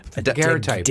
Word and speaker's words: daguerreotype: daguerreotype? – Daguerreotype. – Daguerreotype. Like those old box. – The precursor to the photograph. – Yeah. – So it wasn't photographed, it was daguerreotyped. – It was daguerreotyped.